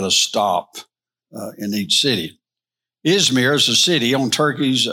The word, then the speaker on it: Izmir: Hanselman pronounced it last week as Izmir.